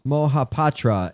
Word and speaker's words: Mohapatra: D-H-A-N-U-R-J-A-Y-A. Danurjaya. – God, you can't afford to buy a vowel. – Mohapatra. M-O-H-A-P-A-T-R-A.